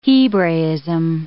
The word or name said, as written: Hebraism